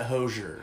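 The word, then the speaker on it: Hozier: No, he pronounces it Hosier.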